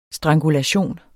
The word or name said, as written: strangulation